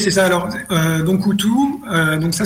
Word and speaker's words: Utu: Euh, Utu, je ne sais pas si c'est comme ça qu'on le prononce, Utu. Utu. Utu Le U se prononce OU.